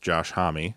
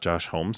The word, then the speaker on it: Homme: It turns out as you and I have already discussed, it is pronounced Josh Homme. – Yeah. – And not Josh Homme, so we can clear that one up.